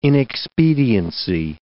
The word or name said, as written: inexpediency